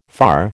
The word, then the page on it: far: American English